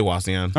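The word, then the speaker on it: Wauseon: Did I say that right? I always mispronounce that. Yeah, I say Wazion.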